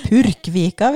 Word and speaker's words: Porskvika: i ei lita vik som heter Porskvika. Jeg lærte meg at det uttales ja. sånn. det er Purkvika, hvis du skal si det på bokmål, altså.